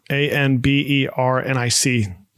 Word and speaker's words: Anbernic: A N B E R N I C, Anbernic.